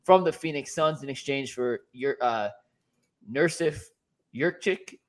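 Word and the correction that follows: Nurkić: I never say his name right. It's Jurkic. Let me just pronounce it properly. They got Yusuf Nurkic back. Nurkic, excuse me.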